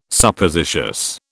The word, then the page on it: Suppositious: British English